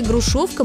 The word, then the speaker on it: грушовка: Есть такой старинный русский сорт народной селекции – грушовка московская. Ударение на втором слоге. Пишется через «о».